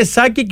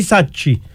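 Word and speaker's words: Sacchi: Τελικά είναι Σάκη και όχι Σάτσι. Έτσι προφέρεται από ό,τι μου είπαν. Τέλος πάντων, ο Ιταλός ο διαιτητής, είδες πώς χειρίστηκε τη φάση.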